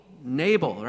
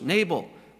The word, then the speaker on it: Nabal: Nabal, right? That's how we pronounce it in English, Nabal.